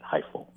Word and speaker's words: HIFL: and are you calling it HIFL, or what's the acronym pronunciation? – Yeah, we do call it that, HIFL.